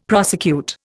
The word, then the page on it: prosecute: Indian